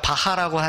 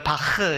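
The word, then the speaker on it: Bach: 이 H에 밑에 이렇게 되어 있으면은 그 독일어 같은 데에서 우리가 바하라고 하는 그 뭐죠? 음악 하는 사람 있잖아요. 그 원래 바흐 이렇게 흐떠 아헤 신 아헤.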